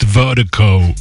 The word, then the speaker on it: VIRDIKO: And Vertigo, Vertigo is spelled V-I-R-D-I-K-O. That's V-I-R-D-I-K-O, pronounced Vertigo.